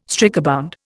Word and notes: strikebound: Indian

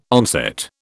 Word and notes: onset: British English

